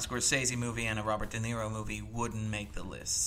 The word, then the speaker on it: De Niro: Bobby De Niro, am I saying that right? – Yeah, you said right.